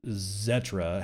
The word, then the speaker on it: Xetra: Bitcoin ETP, however, from Iconic Funds starts trading on Deutsche Börse Xetra today, or Zetra, however you want to pronounce it.